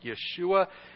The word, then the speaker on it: Yeshua: In fact, uh, the name Jesus, if we were pronouncing it in the Hebrew, it'd be something like Yeshua.